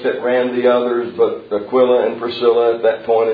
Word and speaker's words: Aquila: If you want to pronounce them like I do, it's Priscilla and Aquila. If you want to put the emphasis on the right syllable, it's Priscilla and Aquila. Take your choice.